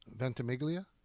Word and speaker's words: Ventimiglia: How do you say his last name again? – Ventimiglia.